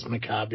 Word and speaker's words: macabre: I believe it's McCobb, yeah. Even though there's an R in it? – R and an E. – Yeah. Back in the day, I used to pronounce it Macabre.